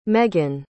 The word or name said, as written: meaghan